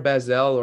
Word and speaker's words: Basel: Is it Art Basel or Basel? I don't know. – Art Basel. – Basel. Oh, geez. Yeah. Sorry.